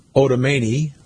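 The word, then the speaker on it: Otarmeni: The therapy, a surgically delivered drug developed by Regeneron called Otomani, rhymes with harmony, recently received accelerated approval from the U.S.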